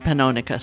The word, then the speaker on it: Pannonica: And she once said to him, he said something about Pannonica, and she said, "Long O, Joel." So she would pronounce it Pannonica, so I always do.